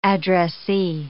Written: addressee

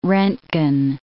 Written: roentgen

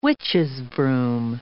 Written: witches'-broom

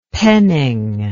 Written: penning

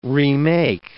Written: remake

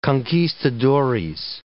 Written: conquistadores

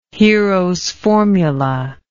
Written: heros formula

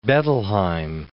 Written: Bettelheim

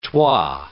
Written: twa

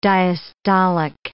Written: diastolic

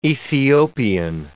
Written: Ethiopian

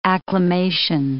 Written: acclamation

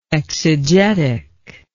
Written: exegetically

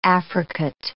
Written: affricate

